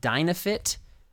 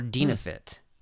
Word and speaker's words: Dynafit: And because it's called Dynafit or Dynafit. I've heard it pronounced both ways. D-Y-N-A-Fit. How would you pronounce it? – I've heard it as Dynafit.